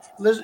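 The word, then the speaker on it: Krueger: Her name is Kroger. I don't want to. I may Liz have mispronounced Kruger. Liz, her, yes, Liz Kruger.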